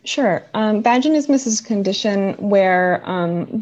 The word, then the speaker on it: vaginismus: Let's let's move on to vaginismus.